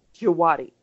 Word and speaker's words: Djawadi: And I have to tell you that for me, the what I was hearing, the music, of course, I'm sure, you know, is Dizwadi, is that how you say it? – Djawadi.